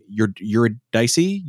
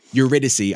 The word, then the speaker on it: Eurydice: So when we were talking about it 3 years ago, what this is, the narrative is it's the adaptation of Orpheus and I can never pronounce her name. You're Dicey. – You're close. – Yeah. Oh. – I know, but this is getting dicey. – That's them. Eurydice. I just did a Google pronunciation thing. Eurydice. – Wasn't even close.